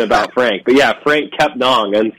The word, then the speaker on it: Kepnang: Yeah, so originally when he came in, everybody, it was Frank Kepnang, you know, and nobody had really corrected us. And I think, to be honest, that's on people like me for not asking Frank. But the proper pronunciation, we know now, is Frank Kepnong. It rhymes with song, Frank Kepnong.